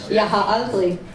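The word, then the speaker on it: Jeg har aldrig: In Danish, Jaha Aldrich. But in American it gets butchered, like literally butchered.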